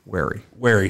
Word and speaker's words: wary: But I'm always a little weary whenever you got people that... – Wary. – Sorry. – Depends on how you pronounce it. – Wary. – Well, if he's weary, he means he's sleepy.